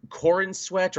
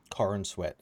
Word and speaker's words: Corenswet: Uh, that, of course, would be David Corenswet or Corn Sweet. I've heard everyone pronounce it differently. Corn Sweat. That's how I pronounce it.